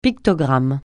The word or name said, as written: pictogramme